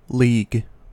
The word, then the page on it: league: American English